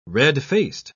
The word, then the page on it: red-faced: British English